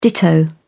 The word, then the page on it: ditto: British English